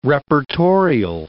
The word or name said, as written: reportorial